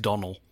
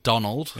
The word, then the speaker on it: Domhnall: It's Donal. It's pronounced Donal. – Donald.